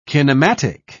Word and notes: kinematic: British English

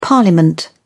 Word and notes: parliament: British English

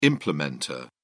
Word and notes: implementer: British English

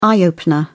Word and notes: eye opener: British English

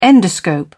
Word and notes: endoscope: British English

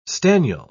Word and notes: staniel: British English

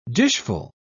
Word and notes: dishful: British English

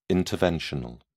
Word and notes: interventional: British English

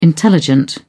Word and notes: intelligent: British English

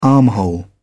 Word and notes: armhole: British English

